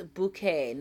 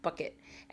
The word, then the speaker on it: Bucket: And that's a thing, guys listening, that literally her name is spelled, she'll spell it B U C K E T, bucket. And they'll go, the person on the other phone will go, she goes, no, it's bouquet. There's no way in the world that that is spelled bouquet.